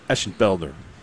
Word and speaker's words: Eschenfelder: That's good pronunciation of his name, too. – Eschenfelder? – Yep.